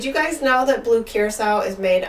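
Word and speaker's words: curaçao: I used to pronounce it blue caraco until, you know, an older friend who was also a bartender was like, you know that's not how you say it, right?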